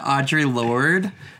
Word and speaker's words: Audre Lorde: Audre Lorde.